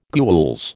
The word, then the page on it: gules: American English